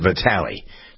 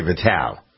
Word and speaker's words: Vitale: All right, as long as we're in the sports mood, Dick Vitale, I always pronounce it Vitale. Some people say Vitale.